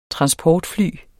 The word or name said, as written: transportfly